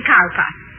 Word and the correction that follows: Cowper: Cooper, darling. – Hmm? – It is spelled Cowper, but pronounced Cooper.